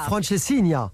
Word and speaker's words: francesinha: Alors, dites-moi si je le prononce bien. La Francescina. Ah, absolument.